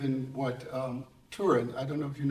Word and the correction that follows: Turing: I think I have his name wrong. Tur- Turing. – Yeah, Turing.